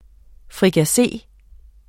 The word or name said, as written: FRIKASSE